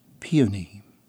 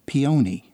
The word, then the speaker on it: peony: My grandmother pointed out in her distinctively schoolteacher voice that the word was pronounced peony, and under no circumstances was it to be uttered as peony, a sure sign, according to her, of a lack of not only knowledge, but probably good breeding.